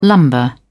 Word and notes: lumbar: British English

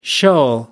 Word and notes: shoal: American English

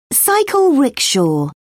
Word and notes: cycle rickshaw: British English

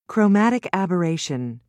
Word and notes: chromatic aberration: American English

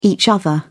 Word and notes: each other: British English

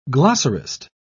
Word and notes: glossarist: British English

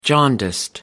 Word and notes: jaundiced: American English

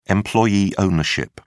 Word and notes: employee ownership: British English

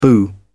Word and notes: boo: British English

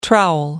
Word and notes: trowel: American English

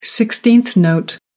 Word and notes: sixteenth note: American English

